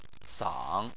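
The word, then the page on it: sang: tone 3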